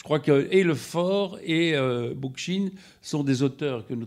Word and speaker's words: Bookchin: Bookchin. – Comment ? – Bookchin, donc ça va, ça.